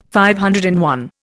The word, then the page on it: DI: Indian